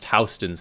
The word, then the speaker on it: Houston: And I will tell you, as a New Yorker, it's actually pronounced Houston Street.